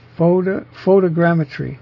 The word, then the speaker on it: photogrammetry: Photogrammetry is how they recommend pronouncing it.